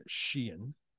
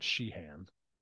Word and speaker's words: Sheehan: I learned how to pronounce his name right. Sheehan, not Sheehan.